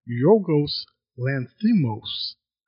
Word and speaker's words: Yorgos Lanthimos: The Lobster is an A24 film directed by Yorgos Lanthimos.